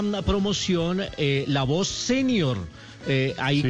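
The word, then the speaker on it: sénior: se escribe con tilde en la E y se pronuncia así como la estoy diciendo, senior, ah, señor, sí, señor.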